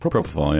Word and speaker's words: propofol: They put me on that propofol. Am I saying that right? – Propofol, yeah.